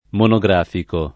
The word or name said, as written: monografico